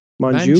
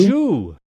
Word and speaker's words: Manjoo: Well, there's a story in Slate magazine by and you're gonna have to say his name, Farhad Manjoo. Manjoo.